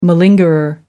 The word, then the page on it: malingerer: American English